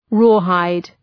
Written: rawhide

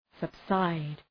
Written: subside

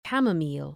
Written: camomile

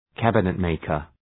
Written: cabinet-maker

